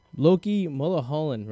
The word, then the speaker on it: Mulholland: Loki Mulholland, right? Is that how you pronounce his last name right? – Yep, you got it right.